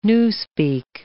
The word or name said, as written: newspeak